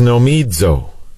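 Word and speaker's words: Nomizo (νομίζω): Now they've translated this supposing, but the Greek word is nomizo, N-O-M-I-Z-O. But remember, in Koine Greek, you always pronounce a Z as if it had a D in front of it.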